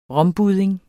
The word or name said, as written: rombudding